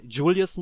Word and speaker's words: Julius: Und der und sein Handlanger Julius, die wollen die Kontrolle, oder Julius muss man sagen, also er wird ja wirklich englisch ausgesprochen, zumindest nach der japanischen, äh, nach dem japanischen Text.